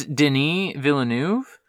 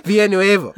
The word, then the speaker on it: Villeneuve: I think it's Denis Villeneuve. But yeah, you and I, for some reason, keep pronouncing it more and more Spanish every time we say it.